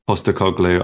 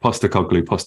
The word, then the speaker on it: Postecoglou: Postecoglou, I said it wrong the first time. I promised myself I would get it right. Postecoglou.